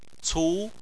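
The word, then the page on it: cu2: tone 2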